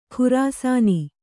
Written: ಖುರಾಸಾನಿ

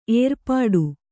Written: ಏರ್ಪಾಟು

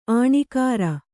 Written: ಆಣಿಕಾರ

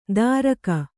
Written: ದಾರಕ

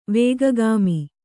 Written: ವೇಗಗಾಮಿ

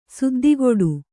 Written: ಸದ್ದುಗಿಡು

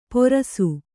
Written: ಪೊರಸು

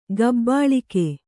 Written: ಕಬ್ಬುಳಿಗ

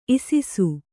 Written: ಇಸಿಸು

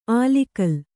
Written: ಆಲಿಗಲ್

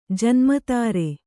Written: ಜನ್ಮತಾರೆ

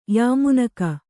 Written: ಯಮಾನುಗ

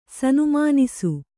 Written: ಸನುಮಾನಿಸು

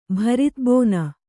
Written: ಭರಿತಬೋನ

